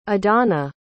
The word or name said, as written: adanna